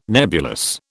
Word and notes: nebulous: British English

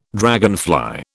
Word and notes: dragonfly: British English

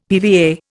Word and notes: PVA: Indian

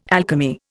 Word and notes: alchemy: Indian

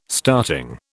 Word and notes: starting: British English

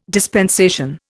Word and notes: dispensation: Indian

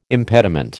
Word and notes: impediment: American English

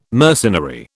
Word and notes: mercenary: British English

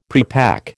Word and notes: prepack: American English

